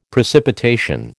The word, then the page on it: precipitation: American English